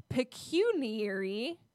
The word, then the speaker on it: pecuniary: I probably said like pecuniary or some dumb shit. Is actually pronounced pecuniary.